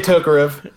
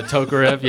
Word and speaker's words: Tokarev: Yes, please just give me a Tokarev. – Yeah, Tokarev.